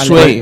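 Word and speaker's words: Zwei: Para mí es Sway, es Marco Sway me eh, sale, me sale solo. es pronunciación alemana.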